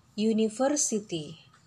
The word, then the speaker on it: university: An hour Nah, kemudian ada juga e university. Kelihatannya ketemu dengan u ya, tapi dia y pengucapannya. E university.